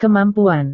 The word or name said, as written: kemampuan